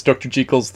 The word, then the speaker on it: Jekyll: I guess Dr. Jekyll's the way you actually are supposed to pronounce it.